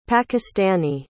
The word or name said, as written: pakistani